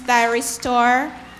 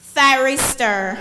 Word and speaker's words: thyristor: Are you sure that this word can be pronounced thyroid store, or you just don't see it in your dictionary? – The dictionary has only one pronunciation, thyristor. – Thyristor. T-H... Y-R-I-S-T-O-R.